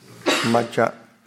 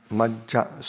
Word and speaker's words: majja: Majja, Majja. So it's like two J's together. Majja.